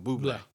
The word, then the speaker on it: Bublé: Oh, did I pronounce it differently? – You said Bublé. – Sorry. Like, no. Yeah. – It's Michael Bublé.